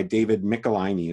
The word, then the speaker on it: Michelinie: It's a 28-issue run, which is written by David Michelinie, is how it's pronounced, who is a really good writer.